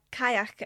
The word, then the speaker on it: Cailleach: We know the Cailleach, or Cailleach as it's pronounced in some parts of Scotland, as an ancient Gaelic environmental deity who built the mountains of Scotland.